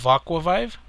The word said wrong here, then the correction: Aquavibe: And he's friends with a lot of gaming YouTubers, and like what he'll do is Aquavibe, I'm sorry I said that wrong, it's D1 of Aquavibe.